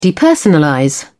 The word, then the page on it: depersonalize: British English